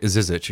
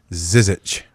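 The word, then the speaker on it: Žižić: Zizic, excuse me. I'm finally figured out how to pronounce his name correctly. – Zizic.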